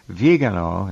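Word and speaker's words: Viganò: Here's Archbishop Vigano, and that's how I'm told it's pronounced, not Vigano, it's Vigano.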